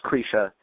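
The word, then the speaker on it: Krisha: Well, and for those listening who aren't familiar, Kreisha is it Kreisha? Kreisha, am I pronouncing it right? – It's pronounced Kreisha.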